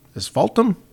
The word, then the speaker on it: asphaltum: and 220,000 gallons of asphaltum oil. Asphaltum? – Asphaltum oil. Okay, I just want to make sure I said that correctly. – That's the root word for asphalt.